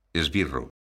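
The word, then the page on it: esbirro: galega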